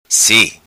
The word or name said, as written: Si